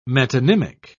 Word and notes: metonymic: British English

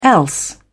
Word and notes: else: British English

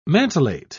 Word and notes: mantelet: British English

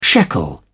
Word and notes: shekel: British English

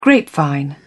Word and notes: grapevine: British English

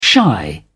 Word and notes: shy: British English

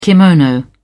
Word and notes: kimono: British English

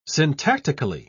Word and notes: syntactically: British English

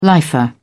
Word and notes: lifer: British English

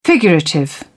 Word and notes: figurative: British English

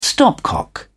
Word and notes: stopcock: British English

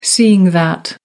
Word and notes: seeing that: American English

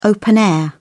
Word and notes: open-air: British English

